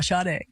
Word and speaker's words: Sade: Yeah, I said it wrong on live on radio. I called him Sade. I'm like, oh, I never lived that one down. So, yeah, Sade.